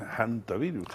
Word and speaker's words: hantavirus: Del antivirus, que otros dicen hantavirus. – Sí, sí, lo pronuncia con la H aspirada.